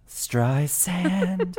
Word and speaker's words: Streisand: Plus, we've got oodles of chart chat oodles of chart chat about Barbara Streisand, Soft S, don't forget, who earns her 11th number one album on the Billboard 200 this week.